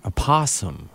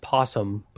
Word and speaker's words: opossum: Opossum. Okay. – That's how you spell it. Everybody thinks it's possum, but it's opossum with an O.